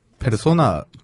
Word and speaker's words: 페르소나: 조연이 얼마나 화려하냐면 스피, 스티브 맥킹 감독의 페르소나를 할 수 있는 아 페르소나 P 발음해야 되나요? – P죠. 네, P요. – 어, 네. 미안해요. 어, F 발음했었어요.